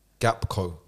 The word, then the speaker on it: Gakpo: Cody Gakpo.